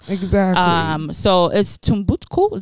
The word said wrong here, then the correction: Timbuktu: is that how you pronounce T- it Timbuktu Timbuktu T I M B U K T U um, I'm just amazed.